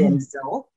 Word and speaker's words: Denzel: Number one, I learned that Denzel, we pronounce his name incorrectly. It's mm-hmm. Denzel, he tells us.